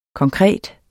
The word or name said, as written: konkret